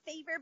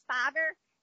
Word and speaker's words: Fiverr: It's called, I thought it was called Fever, but it's actually pronounced Fiverr. It's Fiverr.